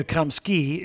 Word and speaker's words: Gramsci: Actually, the Italian, I speak Italian. Antonio Gramsci is actually the way you pronounce it in Italian.